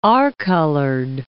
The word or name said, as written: r-colored